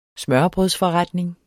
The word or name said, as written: smørrebrødsforretning